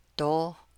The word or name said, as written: dóó